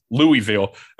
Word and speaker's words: Louisville: I watched more of Wake Forest Louisville than I ever should have. Louisville, that's not how you say it. Louisville.